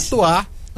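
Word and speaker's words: Chez toi: Chetoar, _ em seu lugar. Chetoar se escreve Chez, e Toar se escreve T-O-I.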